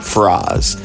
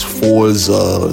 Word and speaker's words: Furoz: It's founded by my bro Froz, and his name is spelled Furoz. Froz. It's not, it's some cats that do know him, they might think it's Forza or something, or Furo. I've even back then when I first met him, I butchered it, but he told me it's Froz, so y'all gotta get it correct.